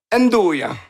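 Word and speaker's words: 'nduja: Le truc dans le milieu, ça, c'est un truc qui se prononce très mal, c'est le Ndjuna? – Ndouya. – Bon. – Andouille, c'est pas loin.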